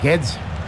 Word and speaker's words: Guedes: Sí, es Guedes. – Gonzalo Guedes. Guedes. Parecía alemán, más que portugués.